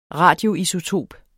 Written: radioisotop

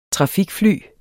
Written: trafikfly

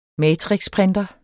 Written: matrixprinter

